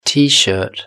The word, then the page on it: tshirt: British English